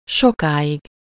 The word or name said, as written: long